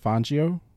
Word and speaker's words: Fangio: Can I update my pronunciation, please? – Yes, please. – I've thought about it, and I'm going to go with Coach Fangio.